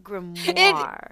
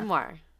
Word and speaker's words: grimoire: Is that how you say it? Grimoire. – It is, but you're like, grimoire. – Well, it feels magical because it is. So, like, well, grimoire. Yeah. – Grimoire.